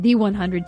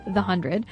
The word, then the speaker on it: The 100: The 100, which, thank you, she corrected our pronunciation from The 100 to The 100.